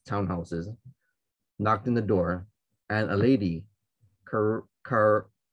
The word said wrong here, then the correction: Corazon: Corazon. – Corazon. How do you say it? Carazon? – Corazon. Amurao. I just saw her picture, actually. Hold on, let me find it. Corazon Amurao. – Amurao. Thank you, Patty.